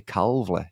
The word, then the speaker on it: Calveley: Calveley, is that how you say it? – Yeah, that's right.